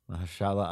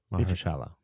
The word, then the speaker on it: Mahershala: Marshall Ali. Sense. Am I saying that right? I'm terrible. – Mahershala Ali. – Yeah. Mahershala.